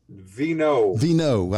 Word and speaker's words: Veinot: Veinot.